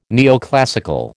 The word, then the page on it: neoclassical: American English